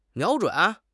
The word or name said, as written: 瞄准